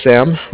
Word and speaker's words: Sam: My name is Sam, like Samuel. Sam. And I'm calling from the Bank of Cardiff. It's spelled C-A-R-D as in David, I-F-F, like Frank.